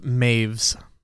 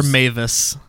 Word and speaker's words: Maives: According to Wikipedia, it's pronounced Maves. – That's it. Maves. – Or Mavis. – Yeah. – M-A-I-V-E-S is how the pronunciation is.